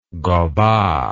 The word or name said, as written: gobat